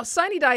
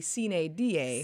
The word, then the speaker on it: sine die: Now, sine die is how people in Georgia pronounce it. It comes from Latin, and if you were pronounce it according to Latin guidance, it would be sine die.